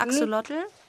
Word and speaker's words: Axolotl: Axolotl? – Eingedeutscht heißt er Axolotl. – Axolotl. – Und aber es ist ein mexikanisches Wort und heißt Acholotl, sagt man, glaube ich. Ist die herkömmliche Ausdrucksweise. Aber Axolotl ist natürlich schöner.